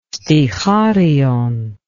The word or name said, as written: sticharion